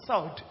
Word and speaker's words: sword: I'm an English teacher, so let me say, that word is not sword, it is sword. You don't pronounce the W.